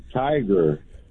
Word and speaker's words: Tigard: it almost sounds like tiger. But it's Tiger. It's like T-I-G-A-R-D.